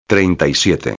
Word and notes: 37: espanhol

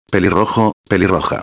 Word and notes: pelirrojo-a: espanhol

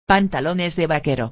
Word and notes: pantalones de vaquero: espanhol

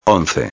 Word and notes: 11: espanhol

